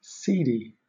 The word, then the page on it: cedi: British English